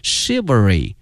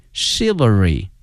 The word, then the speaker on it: chivalry: 今天我们学到了侠客的这个英文的翻译，它的发音是 chivalry， chivalry。同时啊，同学们注意啊，这个词的发音哈，它跟一般的规律不一样哈，这个 ch 没有念成这个 ch 这个音，而念成这个 sh 这个音，所以同学们要小心哈，念成了 chivalry novels。